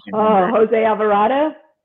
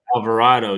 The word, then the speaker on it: Alvarado: Jose Alvarado. – Alvarado, yeah.